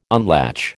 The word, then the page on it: unlatch: American English